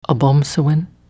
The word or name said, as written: Obomsawin